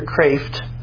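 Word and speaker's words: Kreeft: It's by Dr. Peter Kraft, and that's pronounced Kraft, not Kreef. It looks like Kreef. According to him, I was in the auditorium when someone asked, how do you pronounce your name? He said, Dr. Kraft. So that's how it's pronounced.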